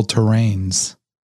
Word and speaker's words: terranes: and by the way, I think the I think it's I think it's pronounced floral terrains. T e r r a n e s.